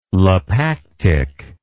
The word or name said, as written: lapactic